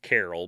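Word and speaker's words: Karel: So, Carla's father, Carol, or however you want to pronounce it, it's K-A-R-E-L, so technically it's Carol, but he immigrated to Canada in the 50s from Czechoslovakia, so it's however they would pronounce it.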